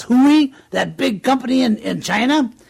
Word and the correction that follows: Huawei: I think it's actually Huawei.